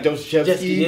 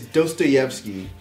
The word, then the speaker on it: Dostoevsky: Even though I love Black Dot, but he mispronounces his name. I thought it was funny. I think he called like Dostoevsky. It's Dostoevsky.